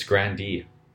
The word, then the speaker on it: Grande: This is totally off topic, but I learned that we've all been pronouncing Ariana Grande's name wrong. – What is it? – It's Grandee. She says Grandee, and she's just never corrected anyone.